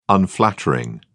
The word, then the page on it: unflattering: British English